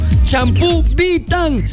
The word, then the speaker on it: Vitane: Champú Vitan con V. – Ajá. – O sea, se escribe Vitane. Con V, Vitane. Okay. Se pronuncia champú Vitan.